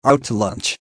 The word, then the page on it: out to lunch: American English